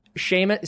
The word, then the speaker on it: Seamus: All right, so my Venmo is at Seamus.